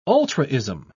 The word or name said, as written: ultraism